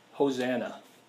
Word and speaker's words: Hosanna: save us," in the Hebrew, it's actually pronounced Hosanna.